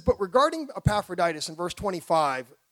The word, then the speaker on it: Epaphroditus: You would be Epaphroditus. But down here in the South, we say it properly. We say Epaphroditus.